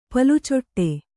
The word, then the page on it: ಪಾಲುಚೊಟ್ಟೆ: Kannada